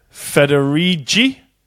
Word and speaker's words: Federighi: In those moments when you just want to type a ducking word, well, the keyboard will learn it too, said Craig Federighi.